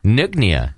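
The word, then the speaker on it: Nøgne: Well, Jeff Green wrote in from Sacramento, and he loves the beers that he's tried, and you might have to help me pronounce this. He loves the beers that he's tried from Nogne? – Nognia. Yeah. – Is that right? – Yeah. – I would never have thought that.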